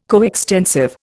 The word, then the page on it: coextensive: Indian